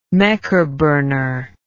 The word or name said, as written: mekerburner